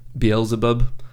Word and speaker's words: Beelzebub: He goes by the devil, Beelzebub, which is, I'm never quite sure how to pronounce that one properly.